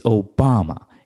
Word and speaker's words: Obama: No, it's Obama. It rhymes with Osama, but Obama is an American.